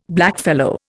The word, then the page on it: blackfellow: Indian